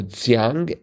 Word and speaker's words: Xiang: 30 across was the Chinese dialect spoken mainly in Hunan province, and the answer was Xiang, Xiang.